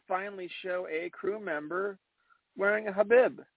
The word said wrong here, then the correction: hijab: Hijab. The pronunciation right.